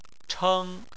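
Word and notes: cheng1: tone 1

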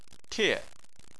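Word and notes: tie: tone 4

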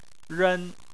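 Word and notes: ren: tone 1

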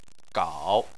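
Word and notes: gao3: tone 3

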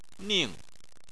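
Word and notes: ning: tone 4

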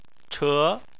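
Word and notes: che2: tone 2

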